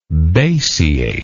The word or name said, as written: basiation